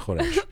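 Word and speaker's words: khoresh (خورش): She's saying lazy people say it, but the official way is khoresh.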